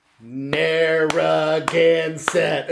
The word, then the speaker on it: Narragansett: walked over, and was like, "Listen, Narragansett." And I'm like, "Oh, okay." She's like, "No, do it with me." So I had to stand there at the bar and clap out the syllables.